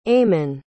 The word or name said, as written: eamon